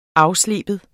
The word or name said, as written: afslibe